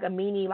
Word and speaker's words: Amini: And I this is saying like Amini, like the dude that did Caroline. It's not the same dude, but it's spelled the same way.